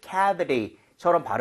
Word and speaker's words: cavity: 자 그리고 cavity보다는 중간에 t가 약간 부드러워졌죠. – Yeah. – Right. 오케이. 그래서 t를 리을 비슷하다라고 생각하시면서 cavity 이런 식 cavity처럼 발음을 하면 제가 두 번 들려드리도록 할게요.